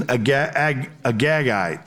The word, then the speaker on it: Agagite: And he's an Agagite, that's the way to pronounce that.